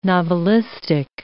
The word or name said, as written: novelistic